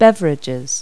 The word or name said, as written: beverage